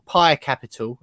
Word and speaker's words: Pi Capital: We opened it, of course, with just clarifying whether you had any involvement in the PAI or Pi Capital, as it's actually pronounced, and that takeover earlier on.